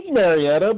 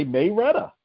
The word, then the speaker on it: Marietta: I think the pronunciation is Marietta, but people here say Mayretta.